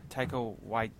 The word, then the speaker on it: Taika Waititi: Talia Waititi. – I thought it was Taika. Taika Waititi.